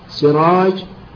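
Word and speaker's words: سِراج: اثنين، المؤتلف في صورة حروفه والمختلف في إعجامها، مثل سراج وسراح، الأول بكسر السين المهملة وبالجيم، والثاني بسين مهملة وحاء مهملة.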